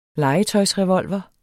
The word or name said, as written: legetøjsrevolver